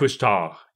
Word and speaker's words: Couche-Tard: I said it wrong again. Wait, Couche-Tard. Couche-Tard. – Couche-Tard, yeah. – I'll never say it as eloquently as you, but I can at least get closer.